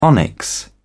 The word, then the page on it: onyx: British English